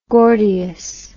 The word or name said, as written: Gordius